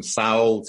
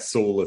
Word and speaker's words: Saul: I get everything from Saul to, I mean, a lot of people just don't get Saul at all. So I say like Paul, but with an S, but that doesn't always help.